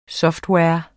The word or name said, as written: software